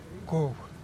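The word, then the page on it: cuhb: Teotitlán del Valle Zapotec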